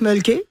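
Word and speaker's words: Mölkky: Alors, ça s'appelle le Mulky. Ça, on prononce Mulky. – Ouais. – Et c'est M-O-L-K-K-Y.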